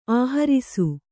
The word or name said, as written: ಆಹರಿಸು